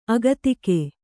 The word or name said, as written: ಅಗತಿಕ